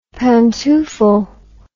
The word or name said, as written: pantoufle